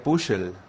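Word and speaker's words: Püschel: Sí, Pusha, dice, se escribe Puschel. Tú que hablas alemán, ¿cómo se pronuncia? – Sería Puschel. – Puschel, va.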